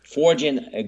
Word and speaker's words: foreign: The G is silent in that word. I know English is hard to master because it has so many oddly spelled words. Foreign is one of them.